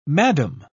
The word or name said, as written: madame